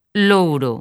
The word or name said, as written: Louro